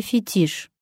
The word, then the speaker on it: фетиш: Но попав на почву русского языка, оно кодифицировано как «фетиш». Это ударение тоже падает на последний слог, запоминайте.